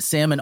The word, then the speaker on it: salmon: But salmon also has an L in the word. It has a silent L because all of a sudden we just decided in that word, the L will be silent.